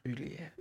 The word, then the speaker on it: Baudelaire: French philosopher Charles Baudelaire says this, just butchered his name. Baudelaire. Baudelaire, I've been practicing that.